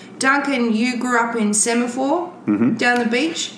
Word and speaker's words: Semaphore: Semen Fork? – Not quite. It's a pronunciation thing, yeah. You got that. – Isn't that what you said? – Really wrong. Semaphore.